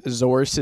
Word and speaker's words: Zorse: Follow him at Zorse Industries. – Yep. – Zorse is spelled like horse with a Z.